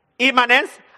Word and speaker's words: immanence: Immanence, I-M-M-A-N-E-N-C-E, immanence.